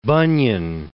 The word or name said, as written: Bunyan